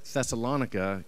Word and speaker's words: Thessalonica: I'm going with Thessalonica because it sounds like harmonica.